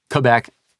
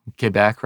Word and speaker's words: Quebec: And Quebec, there's a pronunciation. Mm-hmm. You know what? I mulled over that one. Quebec is how you often hear it, right? And that is mental work for me from years, half of my life or more, French speaker, visited Quebec, right? – Yeah. – And for me, it's Quebec, Les Québécois.